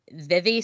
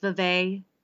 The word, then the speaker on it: Vevey: The world record for the gathering of Charlie Chaplin lookalikes was held in Vevey, Switzerland. There were 662 Charlie Chaplins there. – It's actually pronounced Vevey, and the only reason I know that is because Laurie says that in the Little Women movie.